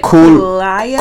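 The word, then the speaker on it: Kaluuya: The star of the movie is, I can't pronounce his last name. – Daniel Kul, it's like Kulaya. – Kulilai.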